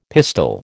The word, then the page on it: pistil: American English